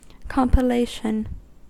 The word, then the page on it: compilation: American English